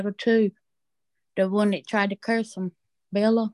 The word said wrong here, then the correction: Balaam: Balaam of Peor.